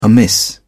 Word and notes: amiss: British English

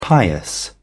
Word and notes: pious: British English

